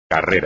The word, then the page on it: carrera: espagnol